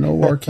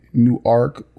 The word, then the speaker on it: Newark: NORK, that's how it's pronounced. I mean, a lot of people say, you know, Newark or mm-hmm. NERC or something like that or whatever. And I tell them, you know, that's in Delaware. – Newark is in Delaware.